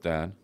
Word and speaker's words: Dan: You're fourth Dan. – Yeah, that's right. – If I said that correctly, that's is that right. Four times black belt? – Yes, it is.